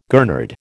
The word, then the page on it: gurnard: American English